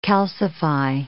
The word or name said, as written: calcify